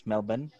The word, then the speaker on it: Melbourne: I crash in whoop whoop, and then I found my way to, let's say, Melbourne, and pretended to be like, yeah, Melbourne. Yeah, yes, that's the correct pronunciation. Yes, Melbourne.